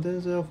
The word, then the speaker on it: Denzel: Your mama call you Denzel, I'm gonna call you Denzel. – His name is Denzel. – Denzel. He said, we've been saying it wrong all this time. His name is Denzel.